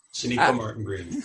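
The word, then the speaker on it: Sonequa Martin-Green: Sonika Martin Green.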